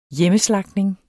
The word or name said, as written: hjemmeslagtning